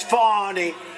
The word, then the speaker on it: Fani: F-A-N-I. That's pronounced Fanny, but you're not allowed to say it. It's Fonny.